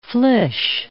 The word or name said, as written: flysch